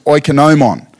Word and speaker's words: oikonomon: And in the Greek, it's actually pronounced oikonomon.